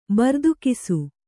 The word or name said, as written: ಬರ್ದುಕಿಸು